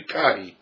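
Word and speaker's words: kahi (καί): In this case, this is the Greek form kai, or kahi. Actually, I'm mispronouncing it. It should be kahi.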